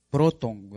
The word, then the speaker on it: protón: Hay diferentes palabras y una de esas palabras es protón. Protón sería hasta aquí. O proton, ¿verdad? Porque en algunos textos esta O lleva tilde, sería proton.